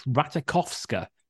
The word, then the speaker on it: Ratajkowski: And previous to recording this, I did have a look online, and she herself pronounced it Ratajkowski. So I think we probably have to take her word for it.